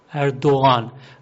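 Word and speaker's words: Erdoğan: این گیه نرم تلفظ میشه. مثلا اسم اردوغان از اینجور غیهایی هست، اردوغان، و تو تلفظ انگار که تلفظم نمیشه، شنیدم نمیشه، میگن اردوغان، اردوغان، آن، انگار که اونا که مثلا اهل زبان نباشند آن میشنوند، غان نمیشنوند.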